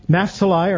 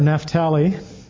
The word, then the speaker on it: Naphtali: Naphtali or Naphtali. I actually looked up how to pronounce this name today, and it could go both ways. I usually say Naphtali, and I hear a lot of people say Naphtali.